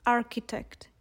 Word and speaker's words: architect: But in English, they don't have the sound h, so they pronounce it as k. So as you can see, they pronounce it architect, even though the h is still in the word written.